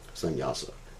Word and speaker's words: sannyasa: Actually, the word sannyas is composed of three syllables, sannyasa, but actually two words, sannyasa.